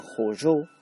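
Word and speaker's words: Hōjō: Este sitio de Odawara era la fortaleza principal del clan Hojo, algo así se pronuncia, ya que son dos os largas.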